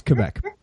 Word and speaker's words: Quebec: It's Quebec. – Yeah, it's Quebec. – It's the simple one. – Most Americans say Quebec. – Yes, right. They do. – But it's Quebec, not Quebec or Quebec.